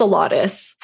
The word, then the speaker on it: Salatas: It's like It's Salatas.